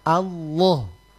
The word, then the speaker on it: Allah: Allah itu dia ditebalkan fakhimil lama, lam pada kata Allah itu lam di tengah, Allah itu ditebalkan. Jadi bacanya kalau minimal orang baca lo lo Allah. Allah, coba bilang Allah.